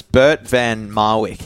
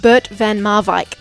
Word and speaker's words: Bert van Marwijk: Firstly, he's Dutch from the Netherlands, Bert van Marwijk. How did I go? – Bert van Marwijk.